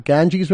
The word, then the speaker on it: Ganges: There was photographs of the Ganges River.